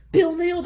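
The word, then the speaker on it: Bill Knell: All right, so I got this on Bill Neal's website, K-N-E-L-L.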